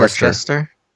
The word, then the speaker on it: Worcester: Worcester. – It's not Chester because it's not CH. Worcester? – No, I know, but it's a long-standing debate.